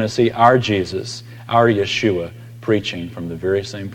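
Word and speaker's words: Yeshua (ישוע): Interesting that Joshua in Hebrew is pronounced Yeshua. Guess how Jesus is pronounced in Hebrew? It's the same. Yeshua is Jesus.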